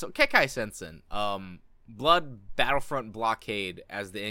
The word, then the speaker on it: Kekkai Sensen: No, Kekkai Sensen is literally four syllables. That's easy. Kekkai Sensen. Two of them are repeating syllables.